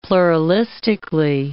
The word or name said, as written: pluralistically